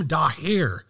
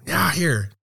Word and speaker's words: Daher: Today, I'm here with Waseem Dahir. Did I get that correctly? – Yeah, Dahir. – Dahir.